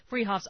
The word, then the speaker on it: Fruhoffs: Freehoff's always has same-day delivery all across the the state. They're spelled Fruhoffs, pronounced Freehoffs, at 33rd and Arapaho in Boulder.